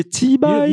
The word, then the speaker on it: cibai: You need to say cibai. – You know how to spell cibai, right? – C-I-B-A-I.